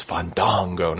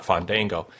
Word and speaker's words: Fandango: Yes, I am glad Fandango stole Bruce Mitchell's gimmick, or my gimmick with Bruce Mitchell, I should say, correcting Josh's pronunciation of his name. It's Fandango, not Fandango.